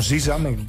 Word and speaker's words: SZA: Artista como SZA, se pronuncia así.